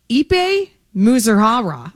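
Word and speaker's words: Ippei Mizuhara: On paper, Ipe Muzuhara. I have not said his name in a long time. Was Shohei Ohtani's. There you go. Did I say it wrong? – You're good.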